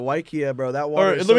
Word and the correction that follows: Waiakea: Now, it's spelled Waiakea. Waiakea.